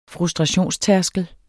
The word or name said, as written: frustrationstærskel